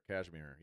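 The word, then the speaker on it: Kashmir: Yeah, so the next song, Cashmere? Am I saying that right? – Cashmere.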